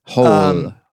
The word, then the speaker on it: Hoel: H-O-E-L. – It seems like, yeah. Oh, yeah. H-O-E-L. – Yeah. – Yeah. Hole. – Hole.